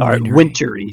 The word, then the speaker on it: Wintory: Austin Wintory? – Austin Wintory, excuse me. I didn't pronounce the T.